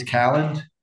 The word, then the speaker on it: Kalland: It's close. It's Kalland.